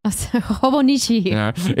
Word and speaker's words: Hobonichi: Hobonichi.